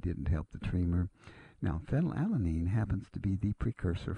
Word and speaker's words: phenylalanine: One of them happens to be the amino acid phenylalanine. If you're not familiar with it, it's spelled P-H-E-N-Y-L. A-L-A-N-I-N-E,